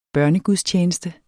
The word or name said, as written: børnegudstjeneste